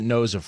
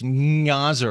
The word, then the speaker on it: Gnoza: That G should be silent. Can you explain why you're not Al Gnoza? – You know, my dad never gave me a decent explanation of that, but everybody pronounces it Gnoza first time. Now, I think in Poland, I think it's Gnoza or something like that. That's the way it's supposed to be pronounced.